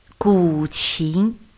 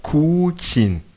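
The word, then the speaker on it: guqin: Gu qin. – Gu qin. – Gu, c'est ancien. – D'accord. – Qin, c'est un instrument. – Ok. Dites-moi si je le dis bien. Gu qin.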